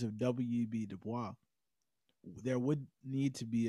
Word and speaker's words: Du Bois: It's Du Bois. It's Du Bois. Yeah, he, within a statement, he clarified the pronunciation.